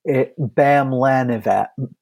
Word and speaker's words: Bamlanivimab: All right, so the proper pronunciation is Bamlanivimab.